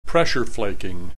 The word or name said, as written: pressure flaking